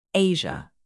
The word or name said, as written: asia